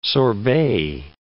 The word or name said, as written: sorbet